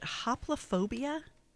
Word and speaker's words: hoplophobia: One of the things that you brought up in our advanced work was, is it hoplophobia? – Right. – Did I say that right? – Right. – Okay, good.